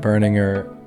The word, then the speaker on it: Berninger: Matt Berninger. Did I pronounce it right, man? – That's right.